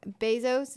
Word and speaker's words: Bezos: 2 decades after its founding, and with Bezos, is that how you say his name? – Yes.